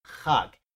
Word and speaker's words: chag: They in the Bible, they, I mean, I'm sorry, in the Strong's Concordance or something, they'll spell it C-H-A-G, but it's not. It's pronounced K-H-A-G, hug. If you see C-H-A-G, you're going to think it's chug, and it's not.